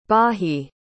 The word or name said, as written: baahi